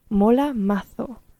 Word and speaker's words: mola mazo: Und zwar wäre das jetzt in dieser Folge Mola Mazo, beziehungsweise wie man es in Spanien aussprechen würde, Mola Mazo.